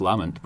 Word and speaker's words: Lamont: Well, in Orkney, because it's a strong name where I'm from in Orkney, it's Lamont. But right. I think he tries to. I think he says it's Lamont.